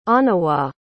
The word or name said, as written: onawa